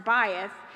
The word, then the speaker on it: bayit: And so the Hebrew meaning for that word, it's spelled bayit, B-A-Y-I-T, but it's pronounced bayith.